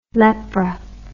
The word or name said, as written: lepra